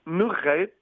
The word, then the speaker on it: Nurate: Est-ce que j'ai prononcé comme il faut Nurate ou il faut le dire autrement? – On dit Nurate.